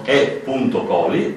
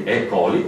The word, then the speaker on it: E. coli: coli, E si pronuncia I e I si pronuncia I, E.